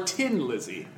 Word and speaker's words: Thin Lizzy: Or Tin Lizzy, as they're known. That's how you officially pronounce their name. – I read about them today. They're an Irish rock group. – That's why you say Tin Lizzy. – Tin Lizzy. – Mm-hmm. Instead of Finn.